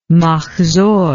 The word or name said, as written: mahzor